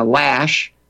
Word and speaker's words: Loesch: The way George just shared the news about Dana Loesch, and I finally got her name pronounced correctly there.